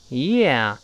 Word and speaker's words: yeah: 这要注意这个 “yeah”，一定不要读成“ 呀呀呀”，也不能读成“ 耶耶耶”，都不对。是 “yeah”，“yeah” 先发 “yeah”，最后发的“ 啊”，嘴要打开。